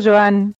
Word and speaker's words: Johan: Em, en este lunes ya está en comunicación con nosotros Carla eh, Joan o Joan, vamos a preguntarle ahora mismo a ella cuál es la pronunciación correcta de su apellido, que es ni más ni menos que sommelier de mate. Hola Carla, ¿cómo estás? – ¿Qué tal? ¿Cómo andan? Eh, perfecto, Joan.